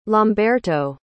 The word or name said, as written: lamberto